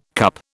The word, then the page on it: CUP: British English